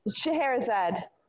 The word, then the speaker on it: Scheherazade: And then as far as these other things, you know, you have this is that Scheherazade? How do you say this? – Scheherazade.